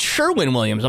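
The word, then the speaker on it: Sherwin-Williams: Oh, it's not Sherman. It's Sherwin Williams.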